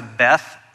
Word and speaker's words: Beth: Anytime you're reading the Bible and you come across a word that has Beth in it, it's pronounced Bet in Hebrew.